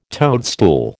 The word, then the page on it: toadstool: American English